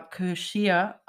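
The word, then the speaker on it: Cù Sìth: Die Schotten haben einen, es ist ein gälischer Name, der etwa Kirschir ausgesprochen wird.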